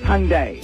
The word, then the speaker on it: Hyundai: It's the Hyundai, by the way. It's pronounced Hyundai.